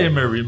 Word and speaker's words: Emery: É Mary, eu não sei como é que fala isso em inglês, mas deve ser Emery. – Ah, na minha época é... do Punks for God falava Emery mesmo. Lá. – Emery mesmo, né?